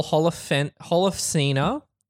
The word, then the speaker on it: Holofcener: I saw that on the Written by Nicole Holofcener, that's how you pronounce it, and Jeff Whitty, and directed by Marielle Heller.